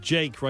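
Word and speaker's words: JVKE: This guy is called his name is Jake, right? But it's spelt with a V. J-V-K-E, right?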